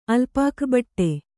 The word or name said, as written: ಅಲ್ಪಾಕಬಟ್ಟೆ